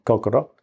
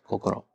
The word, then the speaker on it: Kokoro: Kokoro. – Kokoro.